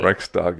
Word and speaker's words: Reichstag: Reichstag fire." Did I say that right? – Reichstag, yes.